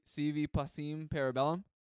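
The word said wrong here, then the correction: Si Vis Pacem Para Bellum: Close enough. And I'm not fluent in Latin either, but this next album is CV Passim Parabellum.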